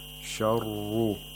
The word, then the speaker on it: شَرُّ: شَرُّ, the ra has tafkhim, and we have to make sure that we don't make a trilling sound when pronouncing the ra.